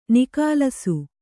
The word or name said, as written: ನಿಕಾಲಸು